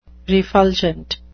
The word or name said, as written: refulgent